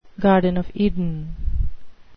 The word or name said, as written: garden of eden